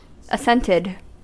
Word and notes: assented: American English

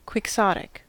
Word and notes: quixotic: American English

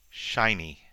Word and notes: shiny: American English

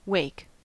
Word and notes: wake: American English

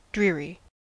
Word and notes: dreary: American English

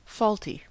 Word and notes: faulty: American English

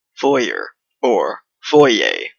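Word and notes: foyer: Canadian English

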